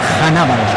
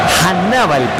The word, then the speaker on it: Hannibal: Que nos comentó antes de la transmisión, ¿cuál es la forma correcta de pronunciar el nombre de el camiseta 10 de Túnez? – Es Hannibal. – Hannibal, perfecto. – Hannibal Mejbri. – Que se termina escribiendo como tal cual, como Hannibal. De la película.